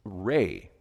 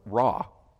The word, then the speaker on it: Ra: Uh, they worship the sun, and one of the primary gods uh, was Re or Ra, depending how you pronounce it.